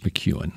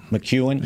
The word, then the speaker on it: McKeon: It, by the way, it's McEwen. McEwen, yeah. I know. Why? What did you call it? It's spelled McKe- McKeon. It's spelled McKeon. Oh, here we go. It's M C capital K E O N. How you get McEwen out of that?